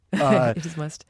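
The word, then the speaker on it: musth: is it must? – Must. – It is must. Talk about that and how dangerous elephants were in that condition and how they coped with that danger. – Must is this phenomenal hormonal state that males come into about once a year, both Asian and African elephants.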